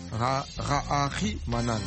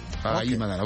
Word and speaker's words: Raharimanana: Raharimanana. Voilà. – Raharimanana. – Raharimanana. Toi, tu ne saurais pas dire ça. Raharimanana. – Raharimanana. – Très bien. Raharimanana, voilà.